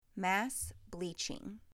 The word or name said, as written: Mass bleaching